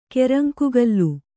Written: ಕೆರಂಕುಗಲ್ಲು

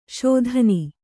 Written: ಸಾದಣ

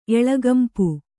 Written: ಎಳೆಗೆಂಪು

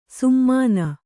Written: ಸುಮ್ಮಾನ

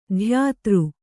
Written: ಧ್ಯಾತೃ

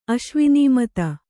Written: ಅಶ್ವಿನೀಮತ

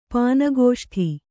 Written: ಪಾನಗೋಷ್ಠಿ

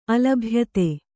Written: ಅಲಭ್ಯತೆ

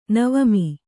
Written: ನವಮಿ

